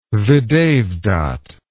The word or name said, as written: videvdat